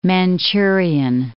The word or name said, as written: Manchurian